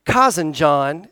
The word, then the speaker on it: Kazanjian: There was a guy by the name of Harry Cousin John. Sounds like he's from East Arkansas, like Cousin John, except it's spelled K-A-Z-A-N-J-I-A-N, and he's Armenian, and he's a jeweler, and he's into fine stones and minerals, and he heard about it.